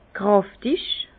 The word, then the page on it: kräftig: Bas-Rhin; Herrlisheim